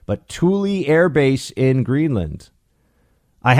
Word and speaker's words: Thule: Keith writes, it's Thule and rhymes with school plus E. Oh, it's Thule Air Base, huh?